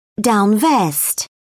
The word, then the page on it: down vest: British English